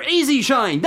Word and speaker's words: E-Z Shine: It's not the word easy written out. It's the letters. It sort of sounds like it when you pronounce the Z. It sounds like Easy Shine.